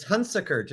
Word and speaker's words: Hunsicker: It's Hunsicker, just like being sick.